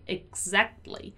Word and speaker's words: exactly: Exactly, exactly. There are many k sounds, right? So exactly, exactly, exactly.